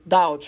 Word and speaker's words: doubt: какие-то слова по типу doubt, э, сомневаться, там же есть буква B, то есть, если ты не произносишь букву B, а говоришь вот doubt, что типа, ну, как правильно произносится, то все окей, и неважно, с каким акцентом ты это делаешь.